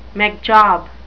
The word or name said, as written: McJob